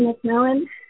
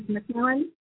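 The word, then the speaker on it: McMillen: Again, my name is Kelly McMillan.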